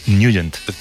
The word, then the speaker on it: Nugent: Hombre, siempre lo hemos llamado en el barrio Ted Nugent o Ted Nugent. – Claro, pero, yo era de Ted Nugent. – Pero la pronunciación correcta es Nugent.